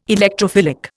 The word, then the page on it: Electrophilic: Indian